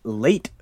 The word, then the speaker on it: Lait: And it is called Late Night Lullabies by Late Fitzpatrick. His first name is spelt L-A-I-T Fitzpatrick.